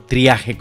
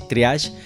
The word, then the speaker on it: triage: o triaje como le dicen algunos, pero se pronuncia así, triage, que es una clasificación para la atención de urgencias en Colombia.